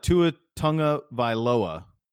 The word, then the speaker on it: Tagovailoa: Do you know that Tua Tagovailoa, which is correct pronunciation, just took me a second to say it out loud.